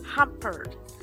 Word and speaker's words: Humbert: Humbert. It's H-U-M-B-E-R-T.